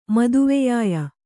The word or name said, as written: ಮದುವೆಯಾಯ